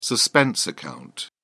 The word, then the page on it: suspense account: British English